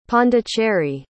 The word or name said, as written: pondicherry